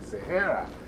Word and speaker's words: Sahara: You know, they don't pronounce Sahara like the way we pronounce it in Africa. It's like Sahara.